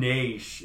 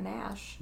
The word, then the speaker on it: Naish: N-A-I-S-C-S-H. Naish? Or Nash? – N-A-I-S... Your guess is as good as mine. – Naish. It's amazing something that's surely just one syllable is so hard to pronounce. – Because it's certainly not Nash.